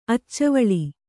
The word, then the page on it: ಅಚ್ಚವಳಿ: Kannada